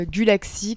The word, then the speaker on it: Gulacsy: Euh, Gulaxi comme euh, Galaxi mais euh, ça s'écrit pas pareil du tout.